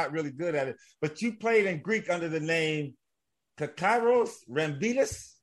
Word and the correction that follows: Kyriakos Rambidis: Yes, you did butcher it. That's exactly what you did. Kyriakos Rambidis. It's a direct translation.